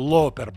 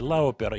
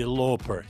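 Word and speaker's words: Lauper: Лопер, правильно, не Лаупер. Я вот по буквам так латыни читаю. Синди Лопер читается.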